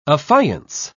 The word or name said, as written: affiance